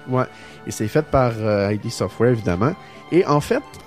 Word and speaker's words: ID Software: Non, mais la prononciation, je remercie Denis Talbot pour ça. – Ouais, c'est ID Software.